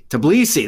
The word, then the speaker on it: Tbilisi: The hubs, which include the existing ones in Lisbon, Portugal, and I've got to say this right, Tbilisi. Is that it? Did I say it right? Tbilisi? Tbilisi, that's it.